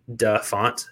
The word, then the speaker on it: DaFont: That's right. I said that correctly. DaFont. D-A font. F-O-N-T.